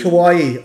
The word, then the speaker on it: Kauai: Kauai. I spoke to his Kauai. – dad. – Okay. – So it's like Hawaii with a K.